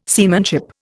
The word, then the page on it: seamanship: Indian